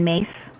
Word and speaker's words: Myss: Okay, so this taps on something that I'm going to point people back to somebody's name that I mentioned earlier, Carolyn Mace. Her last name is spelled M-Y-S-S.